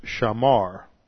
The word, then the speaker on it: shamar: This word is spelled A-B-A-D. Then the second word that is used here is shamar. Shamar, S-H-A-M-A-R.